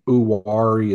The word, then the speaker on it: Uwharrie: Uwari is probably the proper way to say it. Everyone else around here calls it U-R-E. There's actually a race with the letters U-R-E. – Oh, yeah. – So that's how I say it in my head.